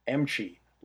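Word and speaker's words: Emchy: And I had to ask my promo friend, I said, "Is it pronounced Emke or Emchi?" And he wrote me back, he's like, "Emchi, like kimchi."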